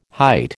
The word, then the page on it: height: American English